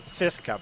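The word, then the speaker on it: CSKA: El Cheska, bueno, en realidad los rusos son el, dicen que hay que pronunciarlo como TS, Cheska, pero es muy difícil.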